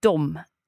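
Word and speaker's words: de: I think what is most important is dom and how that is spelled. So versus how you say it. – Right, so how is it spelled? – So it's spelled D-E. – Without an M? – Yes. – Okay, so what on earth is going on here? – So it's just we have kept the old-fashioned spelling.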